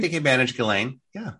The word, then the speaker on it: Ghislaine: That's how you say Ghislaine. – That's how you say it in France. Ghislaine.